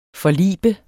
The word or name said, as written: forlibt